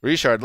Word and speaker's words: Richard: So we got Tywan Jones, DeAndre Washington, and Jalen Richard. Is that how you pronounce that? – Richard.